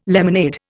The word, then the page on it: lemonade: Indian